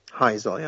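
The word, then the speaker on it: Heysel: Yeah, Heisel, yeah.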